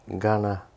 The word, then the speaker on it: Kaurna: Because there is no K sounds in Kaurna language. – Yeah. All right, so it's just common knowledge that it's spelt with a K, but it's pronounced with a more like a G.